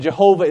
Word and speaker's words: Yahweh: Yahweh is the Hebrew, and Jehovah is another way to pronounce it.